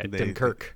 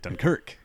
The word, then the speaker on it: Dunkirk: Yeah, if we're talking that type of love for that movie, you know, first of all, we're pronouncing it wrong because the Brits don't say Dunkirk. Yeah. They put the emphasis on the other syllable. – Yeah, Dunkirk. – They say Dunkirk, correct.